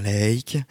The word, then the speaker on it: Laake: L-A-A-K-E. Vous prononcez ça comme vous voulez, moi j'ai tendance à dire Lake.